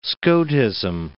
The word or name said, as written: Scotism